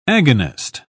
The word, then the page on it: agonist: British English